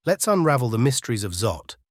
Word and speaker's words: XAUT: Enter XAUT, pronounced ZOT, the brainchild of Tether, a company known for its popular USDT stablecoin.